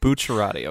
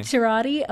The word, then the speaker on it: Bucciarati: Bucciarati, okay. – Bucciarati, okay, thank you.